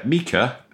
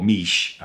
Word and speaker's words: Miche: So it's Mika, as my Italian partner tells me it's pronounced, or Miche, as most of us ignorant on Italian English speakers say.